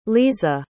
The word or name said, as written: leeza